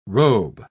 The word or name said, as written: robe